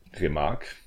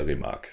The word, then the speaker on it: Remarque: Ja, ähm, Remarque. Erich Maria Remarque, so spricht es jetzt einfach aus. Das ist ein französisch anmutender Nachname.